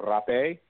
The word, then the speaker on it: rapé: The other types of plants that we use in a ceremony, one is called rapé or japalita. It's spelled R-A-P-E, like rape, but an accent on it, so don't get it confused with the way it's spelled.